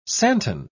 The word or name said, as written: santon